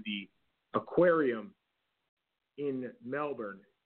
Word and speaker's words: Melbourne: It's Melbourne. They don't pronounce Melbourne. – The R. – They don't. That is correct.